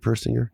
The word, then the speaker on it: Persinger: And did I say that right, Persinger? – Correct.